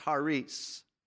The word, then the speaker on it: charis: The Greek word for grace is the word charis. That's how we pronounce it. It's really properly pronounced charis because there's some letters that sound different in the Greek.